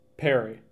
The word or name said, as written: Perry